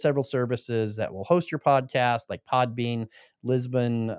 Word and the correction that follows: Libsyn: Megaphone, that, I pronounced that wrong. It's yeah. So it's Libsyn.